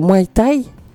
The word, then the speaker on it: Muay Thai: Et c'est Muay Thai.